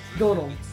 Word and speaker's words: Domhnall: But when you get that last 5 minutes, the whole movie makes more sense with, how do you pronounce the name? Domhnall? – Donald. – Like Donald without a last name.